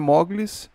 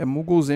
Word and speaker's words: Moogleez: Afinal de contas, meu Twitter é Muggles, não é Mogles, é Muggles, M-O-O-G-L-E-E-Z, Muggles. Se escreve da mesma forma que você escreveria Google, e você não fala Google, ah, realmente fico muito irritado com as pessoas que falam Mugli ou então Moglis de sacanagem, achando que está super, achando que tá sendo super engraçado, mas não é.